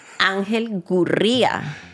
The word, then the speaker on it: Ángel Gurría: This is the way I would pronounce his name. Ángel Gurría.